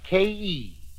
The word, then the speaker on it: Khei: It is spelled K-H-E-I, but pronounced like K-E.